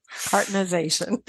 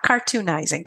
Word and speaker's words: cartonization: Cartonization. Not cartoonizing, cartonization.